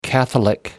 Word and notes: catholic: American English

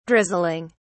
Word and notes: drizzling: British English

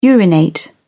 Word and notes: urinate: British English

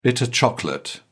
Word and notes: bitter chocolate: British English

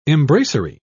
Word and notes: embracery: British English

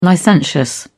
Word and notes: licentious: British English

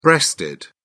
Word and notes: breasted: British English

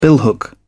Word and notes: billhook: British English

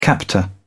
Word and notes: captor: British English

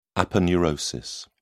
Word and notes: aponeurosis: British English

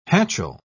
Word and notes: hatchel: British English

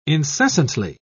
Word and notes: incessantly: British English

